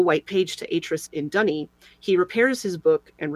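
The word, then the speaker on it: D'ni: They have trapped him in Dunny, as it's pronounced in the game, where the green book leads, by taking a page from his Myst linking book so that he could not return.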